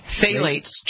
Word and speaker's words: phthalates: Phthalates. Just phthalates. Yeah, ignore the P.